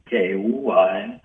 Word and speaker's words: Kauai: The Manihune people kind of had a settlement on that island of Kauai. K-A-U-A-I, I believe that's how you say it, one of the Hawaiian islands.